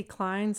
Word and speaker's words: Cline: My name is Nancy Klein, spelled like Patsy Klein, C-L-I-N-E.